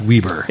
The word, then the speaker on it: Weber: But yeah, there are people that pronounce it Weber mm-hmm. all the time. – It's correctly pronounced Weber.